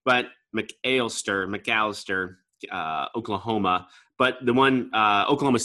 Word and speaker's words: McAlester: I just on the brewery itself, they've got 3 separate locations, all of them in Oklahoma, 2 of them in, is it McAlester? Is that how you pronounce it? – Yeah, sounds right.